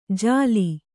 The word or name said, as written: ಜಾಲಿ